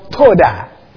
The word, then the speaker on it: todah: There's a Hebrew word, toda. Well, we can spell it in English as T-O-D-A-H, toda.